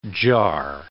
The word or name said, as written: jar